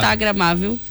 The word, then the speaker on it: Instagramável: É muito legal, muito estragamável aí pra tu... Ó, estragamável é outra palavra, né? Uma palavra inventada aí. Estragamável. – Tu tá falando errado. – Instagramável.